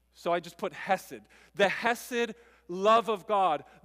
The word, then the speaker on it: Hesed: It's, it's Hesed. And so it's a Hebrew word written in English for you to pronounce Hesed.